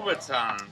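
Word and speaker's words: kubotan: Am I pronouncing it right? Kubaton. – Kubaton.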